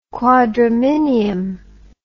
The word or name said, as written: quadraminium